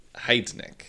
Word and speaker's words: Heidnik: Hidnik? Heidnik. Heidnik. – Heid. It's always in German. It's supposed to be the second letter that you pronounce.